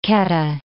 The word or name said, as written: Kedah